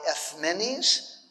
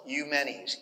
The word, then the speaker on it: Eumenes: It was founded by Ephmenes. If you look at it in textbooks, it looks like Eumenes, E-U-M-E-N-E-S. But I pronounce things the way modern Greeks pronounce them, because my in-laws are all Greek, so I've just gotten in the habit.